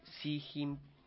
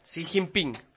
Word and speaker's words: Xi Jinping: Xi Jinping, no pude pronunciarlo, Xi Jinping.